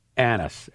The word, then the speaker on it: Annis: It's actually written down, A-N-N-I-S. Now goes, Iowa penalty on number 7, Sam. And then that happened, anus. And then I hear, thank you. Thank you. The dude goes, thank you for saying it right. Everybody says it wrong.